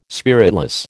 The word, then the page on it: spiritless: American English